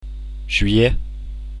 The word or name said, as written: aout